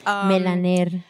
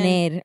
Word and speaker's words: Melaner: Melaner. – Melaner. Oh, is that? Okay, give us the exact pronunciation. – Melaner, which is my real name, but I'm keeping it because it's so unique, and there's no more like it.